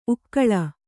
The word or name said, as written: ಉಕ್ಕಲು